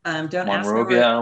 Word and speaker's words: Monrovia: We bought a new truck and happened to be in Monrovia. Is that how you pronounce it? – It is how you pronounce it. – Monrovia, LA.